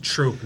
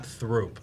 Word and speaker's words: Throop: Is there a producer whispering in their ear, it's pronounced troop, not throop? – Yeah.